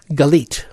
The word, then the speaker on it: Galit: And again, the name of it is, how do you pronounce it? – Galit. – G-A-L-I-T, like mm-hmm.